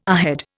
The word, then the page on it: ahead: Indian